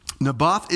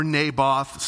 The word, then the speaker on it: Naboth: Naboth is a Jezreelite, or Naboth, some people pronounce it that way.